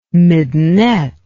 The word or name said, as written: midinette